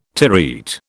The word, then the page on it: terete: British English